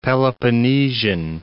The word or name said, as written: Peloponnesian